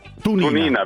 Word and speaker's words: Tunina: Uno dei vini che si è fatto rimborsare è questo, è un vino, non so se lo conosci, come si pronuncia? Taunina? Un vino friulano? – Tunina. Vintage Tunina.